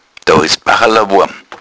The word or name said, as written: bachala